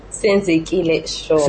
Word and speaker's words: Senzekile Shongwe: Senzekile Shongwe.